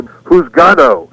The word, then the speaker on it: Juzgado: And that word is spelled J-U-Z-G-A-D-O. And, you know, again, kind of like dar la vuelta. We didn't quite get what they were saying. Juzgado, so we turned it into who's gal.